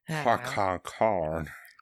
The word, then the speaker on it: Qui-Gon: Qui-Gon. Yeah, pretty much. – Qui-Gon.